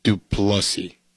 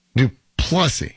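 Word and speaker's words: Du Plessis: That pronunciation, by the way, is Dricus Duplessis. – Duplessis. – Duplessis. – This is. – It's like French.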